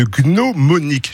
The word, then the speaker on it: gnomonique: Il est question de gnomonique. J'ai bien prononcé? – Bravo, très bien.